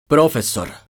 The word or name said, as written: profesor